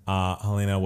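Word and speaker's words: Halyna: Is that how you say her name? Helena mm-hmm. Hutchins, and wounded the director Joel Souza. Helena was 42, and you know.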